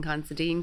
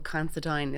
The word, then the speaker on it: Considine: It's been Constantine, Constantine, um, but Considine is what it will be.